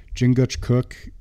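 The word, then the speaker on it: Chingachgook: Like, what a fucking, like, there's no better guy than those three guys than Hawkeye and fucking Jingachgook. Did I say that right? – Yes. – Fuck Jingachgook.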